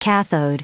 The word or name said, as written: cathode